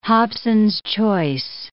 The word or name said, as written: Hobson's choice